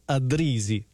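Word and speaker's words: Addrisi: per parlarvi di Dick Addrizzi, o Addrizzi volendolo pronunciare in italiano,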